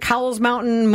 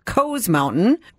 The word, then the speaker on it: Cowles Mountain: So two and a half years later, my friend Dusty invited me uh, to hike, and I have always called it Cowles Mountain. Most people I know around here call it Cowles Mountain, but apparently it's actually pronounced Coes Mountain, we decided to tackle that.